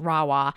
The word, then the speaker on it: Rahua: So it's spelled R-A-H-U-A. It's pronounced Rawa.